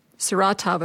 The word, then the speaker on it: Saratov: My family and I, over the holidays, watched the Sarov, I always say this wrong, Saratov approach, and I was really touched by that movie.